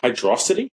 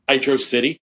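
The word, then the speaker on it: Hydrocity: Pronounce it Hydrosity? – Yeah. It's Hydrosity. – It's not Hydro City? – It's both. It's whatever you want to call it. I call it Hydrosity, but it's also Hydro City.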